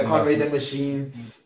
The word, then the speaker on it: Conway the Machine: And we started off with, is it Conway the Machine? Did I say it right? – Yeah, Conway the Machine.